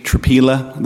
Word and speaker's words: Tropila: For the record, my name is Mitch Tropila. That is spelled T as in turtle, R-O-P as in pie, I-L-A.